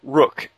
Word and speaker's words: Ruhk: This one here is from It's from Rook, and he actually puts at the bottom, it's spelled R-U-H-K, and he says, pronounced like the chess piece, by the way.